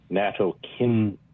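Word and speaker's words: nattokinase: One is it's pronounced natokinase.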